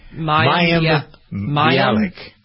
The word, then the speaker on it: Mayim Bialik: Mayim Bialik? – Mayim. – Is that it? – Mayim Bialik.